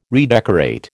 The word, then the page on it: redecorate: American English